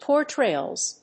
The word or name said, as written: portrayals